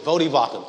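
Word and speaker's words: Voddie Baucham: A guy by the name of, I think his name is Vodi or Vodi Bakum. Vodi Bakum. Thank you. I always pronounce his name wrong.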